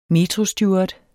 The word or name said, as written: metrosteward